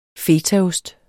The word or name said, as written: fetaost